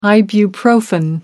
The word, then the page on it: ibuprofen: American English